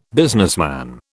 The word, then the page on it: businessman: British English